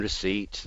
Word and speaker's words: receipt: The silent P in receipt, the silent B in subtle, the silent L in salmon, and so on.